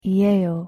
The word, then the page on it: 이에요: Korean